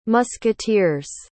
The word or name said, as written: musketeers